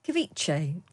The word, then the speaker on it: ceviche: Ceviche.